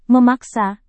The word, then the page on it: memaksa: Indonesian